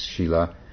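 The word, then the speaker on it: sīla: The original word is Shila, S-I-L-A, pronounced Shila.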